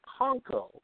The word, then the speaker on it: Panko: But it's a Japanese breadcrumb, and the proper pronunciation is Panko. P-A-N-K-O is Panko.